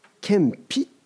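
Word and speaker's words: 謙卑: ちょっと専門用語なんだけど、キリストの献匹、謙遜の献に、 8 節に卑しくしとありますけれども、卑しいと書いて献匹と読みます。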